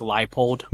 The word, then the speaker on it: Leipold: Now it's my turn to correct you guys. It's Lance Leipold.